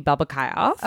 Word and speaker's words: Babakioff: So Facebook, my full name, Shelby Babakayoff. – Okay. – So I'm going to spell it B-A-B-A-K-I-O-F-F.